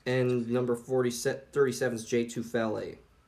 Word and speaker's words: Tufele: 37th overall pick, the Philadelphia Eagles select Jay Tufele. I think that's how you pronounce his last name. – Yeah, Tufele out of USC. Tufele, yeah.